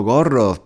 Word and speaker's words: gorro: Em, además, además es una palabra que va muy bien, va muy bien para practicar la pronunciación de la R Gorro, gorro.